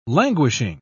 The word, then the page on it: languishing: British English